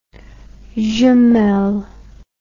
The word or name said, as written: jumelles